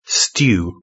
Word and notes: stew: British English

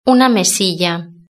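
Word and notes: una mesilla: Spanish (Spain)

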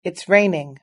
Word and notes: it's raining: British English

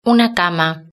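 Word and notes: una cama: Spanish (Spain)

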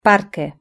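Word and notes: parque: European Spanish

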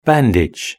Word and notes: bandage: British English

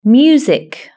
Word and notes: music: British English

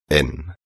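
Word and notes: en: Spain

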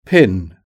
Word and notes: pin: British English

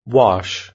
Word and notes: wash: British English

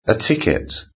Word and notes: a ticket: British English

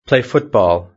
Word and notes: play football: British English

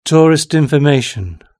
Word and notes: tourist information: British English

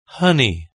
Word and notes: honey: British English

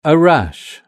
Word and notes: a rash: British English

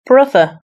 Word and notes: brother: British English

